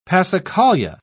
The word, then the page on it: passacaglia: British English